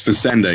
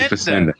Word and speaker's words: Fassienda: You are close. You added an extra pizzazz that I like. It's Fassienda, yeah. Fassienda. Jamie Fassienda.